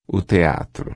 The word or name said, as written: teatro